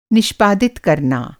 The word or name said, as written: निष्पादित